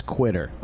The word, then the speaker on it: 2uitter: He spells it the number 2, U-I-T-T-E-R, which looks like Twitter if you were to pronounce it, but it's actually supposed to be a 2, like a cursive Q, I guess. It's Quitter.